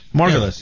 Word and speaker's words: Margulies: Margulies.